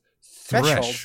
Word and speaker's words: threshold: Threshold. – Thresh. – No, thresh. – Thresh. – TH is hard to emphasize. It's just go threshold.